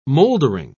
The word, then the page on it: mouldering: British English